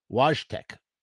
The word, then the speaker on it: Wojtek: Wojtek, 1942 to 1963, Wojtek, so it's not with a W in Polish. First of all, it's Polish, not Russian. Pardon me. Sometimes in English, sometimes it's spelled Wojtek. Wojtek.